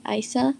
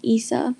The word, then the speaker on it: Isa: Even though a lot of people get my name wrong and call me Isa, the correct way to say my name is Isa, so the I makes an E sound.